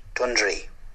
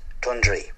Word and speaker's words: Dunderry: The locals pronounce it Dundry. Dundry.